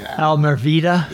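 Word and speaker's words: Almereyda: Almervida.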